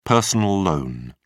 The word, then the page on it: personal loan: British English